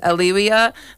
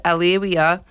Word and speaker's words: Alewya: So if anyone can help me out, I do believe it's Aaliyah. Her name is spelled A-L-E-W-Y-A,